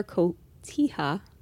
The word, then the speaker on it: cotija: It's pinto bean, guacamole, zesty salsa, and a cheddar cotija cheese blend. Aren't you proud of me? I said that right? – Very, very, very, very.